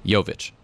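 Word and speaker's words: Jović: But how do you pronounce the J in his name? – Jovic. – Jovic. But for Jokic, it's... – It's Jokic. – Jokic. – It's all, they're both Yo.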